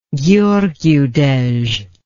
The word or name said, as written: gheorghiu dej